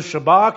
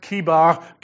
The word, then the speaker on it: Chebar: I was among the exiles by the Chebar Canal. It's probably better pronounced Kibar Canal.